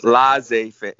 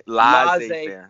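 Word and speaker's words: laissez-faire: Laissez faire. Laissez faire.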